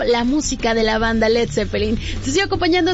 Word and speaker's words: Led Zeppelin: Peter Grant, su manager, sugirió quitar la A y evitar, no, ahora sí que Estados Unidos lo pronunciaran como Lead, y así nació Led Zeppelin.